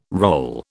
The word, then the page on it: role: British English